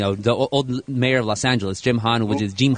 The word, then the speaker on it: Los Angeles: but when you talk about pronouncing it correctly, though, I mean, you can say Los Angeles is the correct pronunciation. But in the United States, the city is really now called Los Angeles.